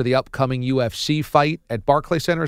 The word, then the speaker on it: Barclays: Right, it's Barclays, almost like Charles Barkley.